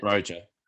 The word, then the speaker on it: Broja: Broja, is that how I pronounce it? – Broja, yeah, Broja.